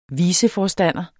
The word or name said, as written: viceforstander